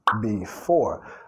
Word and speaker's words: before: Before, acentuando la segunda sílaba ahí, before, before the storm.